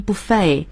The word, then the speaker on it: buffet: 是这样的，我们叫 buffet, 但实际上这个单词在欧美人的读法中它读成 buffet, 是法语词直接过来的，最后的 t 不发音， buffet 是最时髦的读法。